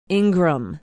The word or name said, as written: ingram